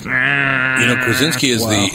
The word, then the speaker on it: Krasinski: Krasinski. – Yes, sorry about that. – Oh, John Krasinski.